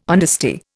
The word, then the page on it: honesty: Indian